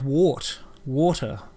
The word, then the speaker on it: water: We say the word water weird. – Water. – Yeah. So when the lady says it on this video... – I say it like wart. Water.